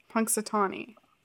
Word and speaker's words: Punxsutawney: Did you know that that's how that's pronounced? Punxsutawney? – Punxsutawney. – Punxsutawney. – You have to say it in an annoying way.